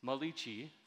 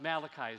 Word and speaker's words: Malachi: We can find this kingdom of God woven through the scriptures from Genesis all the way through, I always say Malachi, right, the Italian prophet, but Malachi is the right way to pronounce it.